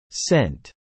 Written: scent

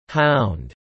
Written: hound

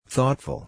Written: thoughtful

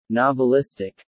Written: novelistic

